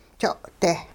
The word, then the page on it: jo'-deh: hupa